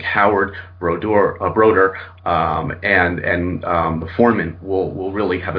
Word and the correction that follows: Brodeur: His dad actually put a post on on IHO and said it's Brodeur like motor.